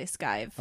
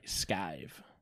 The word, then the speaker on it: skive: Are you sure that's how you pronounce that? – Skive? – I would say skive. – Okay, sorry, skive. S-K-I-V-E.